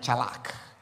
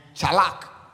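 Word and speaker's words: Tschalach: Number one, for Aman and Chalak. Say Aman. Aman and Chalak. Aman is spelled A-M-A-N. A-M-A-N. Aman. And Chalak is spelled T-S-C-H-A, T-S-C-H-A-L-A-C-H. Chalak. Say chalak.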